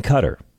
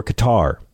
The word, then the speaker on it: Qatar: He spent 6 months loading planes at an airbase in Qatar or Qatar, if you swing that way.